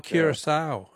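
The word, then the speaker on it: Curaçao: And I don't know if I'm pronouncing it right, but I thought it was Curacao. – Curacao, yeah. No, that's very good. I'm sure you're pronouncing it correctly, but it's not Dutch West Indies.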